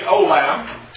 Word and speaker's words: olam: The Hebrew word translated everlasting, perpetual, forever is olam.